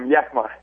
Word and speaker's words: Yakima: Did I say that right, Yakima, or is it Yakima? – Yakima. – Okay, yep. Yakima.